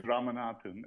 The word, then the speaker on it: Ramanathan: Pretty close. It's Ramanathan. – Ramanathan. You just told me I've been practicing. – Yeah, the second half is the lower register.